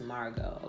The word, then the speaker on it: Margeaux: And it's kind of spelled how we spell things in, like, Creole. So, it's like Margot, M-A-R-G-E-A-U-X. But it's pronounced Margot, okay?